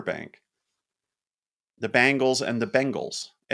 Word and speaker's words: Bengals: But I hear people pronounce it Bangles. – No, it's Bengals.